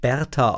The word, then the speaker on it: Beata: Wobei ich erst später rausgefunden habe, es spricht sich Berta aus oder wir verstehen Berta, aber es wird Beata eigentlich geschrieben, aber Berta, ich habe es immer Berta genannt.